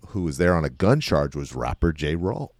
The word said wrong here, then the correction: Ja Rule: Oh, Ja Rule? – Ja Rule.